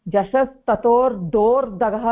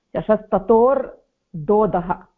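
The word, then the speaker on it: झषस्तथोर्धोऽधः: समीचीनं समीचीनं तर्हि अत्र सूत्रस्य विषये तत्र उच्चारणार्थम् अहं वदामि जशस्ततोर्दोदः जशस्ततोर्दोर्दगः इति न भवती उक्तो सम्यक्तया उक्तवती परन्तु अहं बहुधा श्रूयते जशस्ततोर्दोर्दः इति न तथा नास्ति जशस्ततोर्दोदः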